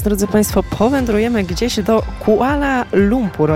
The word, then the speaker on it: Kuala Lumpur: Oczywiście bardzo dobrze to wymawiasz, Kuala Lumpur, nazwa aż znowu nie jest taka trudna.